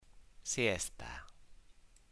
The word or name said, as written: siesta